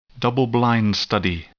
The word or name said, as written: double-blind study